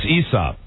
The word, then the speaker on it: Aesop: It's always kind of a silent A, too. Even though it's Aesop's fables, it's like... It's not really like Aesop. – It's Aesop. – Right. – Aesop. Right. – It should be the A is silent.